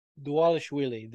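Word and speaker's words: Dvalishvili: Um, moving on to Jose Aldo versus Merab Dwabashvili. – Dwalishwili. The V's are pronounced Dwa, like W's.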